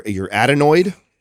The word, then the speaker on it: adenoid: So it's the, and I don't know if I'm pronouncing this right, it's your adenoid.